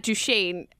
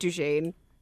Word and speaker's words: Duchene: We talk about Matt Duchene. Is that how you say his name? – That is how you say his name.